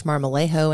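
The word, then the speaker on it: Marmolejo: I am your host, Amanda Pierce Marmalejo, and I'd love to connect with you on LinkedIn.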